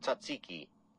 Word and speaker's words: Tzatziki: I didn't know I was saying it that far different from that. Tzatziki. I guess it's got a... Or what are we saying? That it's got a silent T or something?